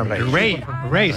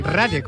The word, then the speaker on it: Raye: Ray. – Se escribe Raye. Raye, con Raye, ya Raye.